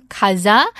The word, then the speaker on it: 가즈아: And this expression, kaza, is simply an elongated pronunciation of kata which is let's go.